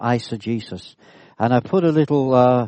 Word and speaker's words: eisegesis: This word is pronounced eisegesis. Okay, I'd like to say that word. Eisegesis, that's how it's pronounced.